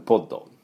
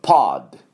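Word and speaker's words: pod: はい、オーストラリアで見ましたけど、まあね、かわいそうなような間抜けのような話なんですが、でこのじゃあシャークポッドね、がこれから人を救うだろうと、これもいいですね。はい、では、この発音なんですけども、この、まあ、シャークポッドね、これ日本でポッドポッドって言いますが、アメリカ人発音するとパッド、ちょっとアに聞こえますね、パッド。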